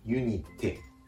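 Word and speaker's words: unité: ユニテですが、あえて言うと語末のて」がポイントでしょうか。フランス語のえ」の発音、これは日本語のカタカナ音 A よりも口を大きく開きます。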